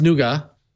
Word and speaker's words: Nougat: It's pronounced Nuga.